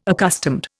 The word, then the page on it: accustomed: Indian